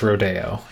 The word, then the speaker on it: Rodeo: Is that the correct pronunciation if we're in Copeland? – If we are if we're talking about the Copeland Ballet, it is Rodeo.